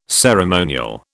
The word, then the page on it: ceremonial: British English